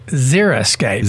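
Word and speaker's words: Xeriscape: It's not zero. It's Xeriscaped. – Xera. Right.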